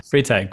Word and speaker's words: Freytag: Did I pronounce that right? – Yeah, Freytag. Gustav Freytag.